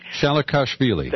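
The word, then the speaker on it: Shalikashvili: General Shashvili. It's so hard to say. – Shalakashvili. – That one. That's well said.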